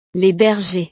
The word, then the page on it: les bergers: frances